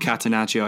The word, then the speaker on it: catenaccio: Yes, it is called catenaccio. I just looked it up. – Okay. – Let's go. – How's your pronunciation on that one? Sounds pretty spot on. It's pretty spot on.